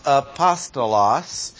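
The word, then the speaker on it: apostolos (ἀπόστολος): which is pronounced apostolos.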